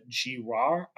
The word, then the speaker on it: Girard: And since the early 70s, it had been led by a man named Joseph Girard, I think is how it's pronounced. – Yeah, I think so.